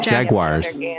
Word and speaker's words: Jaguars: Jaguire is the team. – No. It's Jaguars. – Jaguar is the car. – It's the people in Florida are saying it wrong. It's the Jaguars. They're not Jaguars.